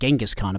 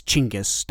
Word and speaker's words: Chinggis: I said Genghis Khan a bunch. It's Chinggis. That's the, like, more authentic pronunciation, apparently.